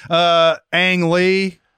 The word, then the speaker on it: Ang Lee: Ang Lee won for Life of Pi. – Another hard name to pronounce. – Yeah, very hard. Very. – I was calling him Ong for the longest time.